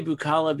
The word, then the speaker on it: Bookala: And I say Bukala because it's like boo. It's spelled B-O-O-K-A-L-A. – Yeah. – Bukala.